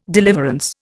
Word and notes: deliverance: Indian

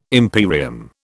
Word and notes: imperium: British English

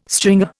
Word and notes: stringer: Indian

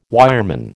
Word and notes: wireman: American English

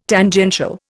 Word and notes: Tangential: Indian

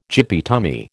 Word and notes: gippy tummy: American English

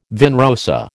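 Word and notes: vin rosé: American English

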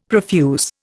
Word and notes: profuse: Indian

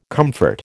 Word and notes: comfort: American English

